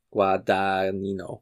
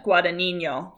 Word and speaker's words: Guadagnino: I mean, I would probably not pronounce that second G and say it's like Luca Guadagnino, something like that. – Yeah, Guadagnino. – Yeah. – I would probably say that sounds something like that.